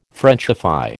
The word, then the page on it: Frenchify: American English